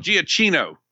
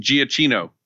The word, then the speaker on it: Giacchino: So it's actually Giacchino. – Bless you. Yeah. That was coming at some point in this show. Yes. That was that's the official who's met the guy pronunciation, Giacchino.